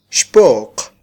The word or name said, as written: xpo'q